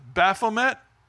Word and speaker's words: Baphomet: And they call it Baphomet. Kind of rhymes with bath mat, but it's the goat-headed demon, Satan, being worshipped by little children.